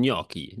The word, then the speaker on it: Gnocchi: Kannst du das bitte nochmal aussprechen? – Gnocchi, Gnocchi. – Ah, sehr Gnocchi. – Gut, sehr gut. Der Italienischkurs trägt Früchte.